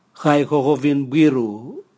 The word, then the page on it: gaigogovinbwiru: Raga